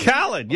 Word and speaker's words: Khaled: Last night they had this rap singer Khalid. – DJ Khaled, yeah, and another one.